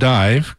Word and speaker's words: Daimh: And the Scottish Highland folk group, Scottish Highland folk group Dive, spelled D-A, and A has that little tilde, not a tilde, but that little mark above it, D-A-I-M-H, but pronounced Dive, according to the band that was here and told me years ago, doing, I'm going to probably butcher this, the Goth a la Gabosh, something like that.